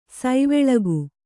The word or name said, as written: ಸವಲಕ್ಕ